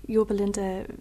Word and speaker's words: Yorba Linda: So Chrissy, you planted and led the Yorba Linda Vineyard. Mm-hmm. I hope I said that right. – Yeah, that? – You did. Good.